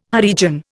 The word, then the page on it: Harijan: Indian